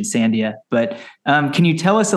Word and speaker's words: Sandia: It is pronounced Sandia. That means watermelon in Spanish.